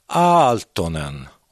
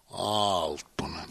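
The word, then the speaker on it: Aaltonen: Jag hörde att när det är två vokaler så ska man uttala dem. Men det är a, så ska man säga Altonen. – Altonen.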